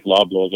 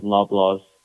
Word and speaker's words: Loblaws: And Leaf Loblaws also, if I'm not mistaken. – Yep. – Did I pronounce that right? – Yep. We just launched our frozen patties in Loblaws, 330 plus locations.